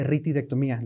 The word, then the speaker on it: ritidectomía: Oye, hace rato mencionabas la parte de ritidectomía, ¿lo dije bien? – Sí, sí.